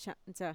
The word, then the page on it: sha'ntxa: Mixtec